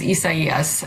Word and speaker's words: Isaias: I mean, the actual correct pronunciation is Isaias.